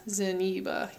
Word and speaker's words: Zeniba: Yeah, Zeniba. – Okay, so it is the same amount of syllables. Yeah. Yubaba, Zeniba.